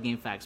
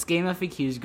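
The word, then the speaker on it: GameFAQs: I always called it Game Facts, but I don't think that's the pronunciation of it. It's Game FAQs.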